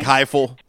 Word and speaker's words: Heupel: When that came across this morning that they had, and help me with his name, Josh Heupel, I believe. – Yeah, you're right. – How you pronounce it. It's like Heupel. Like, it sounds like an F sound, Heupel.